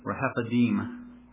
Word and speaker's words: Rephidim: but the pronunciation I got was Rephidim.